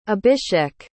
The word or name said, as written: abishek